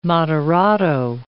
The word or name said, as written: moderato